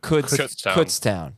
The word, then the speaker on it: Kutztown: Somebody told me it's pronounced like the word could. – Like could. – It is pronounced like that. – Kutztown.